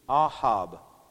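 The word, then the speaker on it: ahab (אהב): The word in Hebrew comes from the Strong's number 157, and it's AW/AHAB, ahab, okay, ahab. That's how I would pronounce it.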